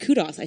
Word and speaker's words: kudos: And so it was called Kudos, and then somebody over here said kudos, I swear to God, and I was like, oh, that's how the British say it. – No, we say kudos.